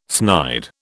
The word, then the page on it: snide: British English